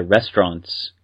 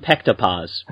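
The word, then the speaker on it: restaurant (ресторан): well, we called them pektapas, because that's how we would have pronounced it in English. – Yeah, in English they would have been pektapas, but in Cyrillic, restaurant.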